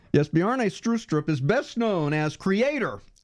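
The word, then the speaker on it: Bjarne Stroustrup: The proper Danish pronunciation of my name is Bjarne Strustrup. Native English speakers usually get Bjarne right, but I often have to say Strustrup or Straustrup to be understood.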